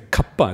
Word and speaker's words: kappa: It's kalpa is the Sanskrit word. In Pali, we say kappa. It's the same word, a slightly different pronunciation.